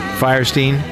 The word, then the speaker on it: Fierstein: Fierstein.